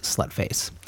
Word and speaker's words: Slutface: So S-L, null symbol T-F-A-C-E, but pronounced Slutface.